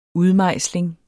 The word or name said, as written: udmejsling